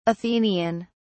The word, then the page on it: athenian: British English